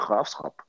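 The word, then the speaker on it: Graafschap: Und in, auf, in Holländisch heißt es halt die Grafschap. Und äh, da wird halt SCH ausgesprochen.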